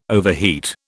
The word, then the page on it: overheat: British English